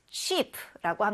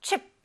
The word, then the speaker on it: cheap: 그래서 값이 싼, 값이 저렴한을 cheap라고 합니다. 자, 따라해 보세요. cheap. cheap. cheap. 여기에서 chip 이렇게 너무 짧게 발음을 하게 되면 우리 감자칩 얘기할 때그 칩이 되니까 cheap 이렇게 길게 발음을 해주세요.